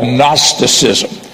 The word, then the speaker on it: Gnosticism: It's spelt with a G, G-N-O-S-T-I-C-I-S-M, Gnosticism. And it's spelt with a G in the front of it, Gnosticism. But it's pronounced without the G. It's G is silent, Gnosticism.